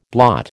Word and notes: blot: American English

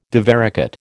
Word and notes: divaricate: American English

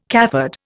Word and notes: cafard: Indian

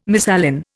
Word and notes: misalign: Indian

